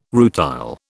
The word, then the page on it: rutile: British English